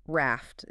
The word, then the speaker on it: RAFT: So RFT, and it's pronounced just like the boat, RAFT is an acronym.